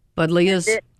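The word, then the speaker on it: Buddleia: I was thinking of maybe one or two dwarf budleys. – Budleias? – Oh, yeah.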